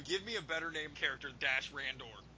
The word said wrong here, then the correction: Dash Rendar: Dash Rendar? You mean Dash Rendar?